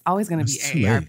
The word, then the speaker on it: AARP: AARP, which is formerly known as the American Association for Retired Persons, but is now trying to go by ARP, which rhymes with harp,